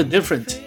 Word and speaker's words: different: 第一句唱词里边有三个 different，结尾的 t 全都不发音。